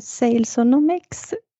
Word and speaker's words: Salesonomics: Och rätta mig om jag har fel, men Salesonomics, uttalar man det så? – Jajamensan. – Berätta lite mer om det.